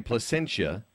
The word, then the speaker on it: Placentia: Well, we say Placentia, but Placentia?